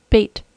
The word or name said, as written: bait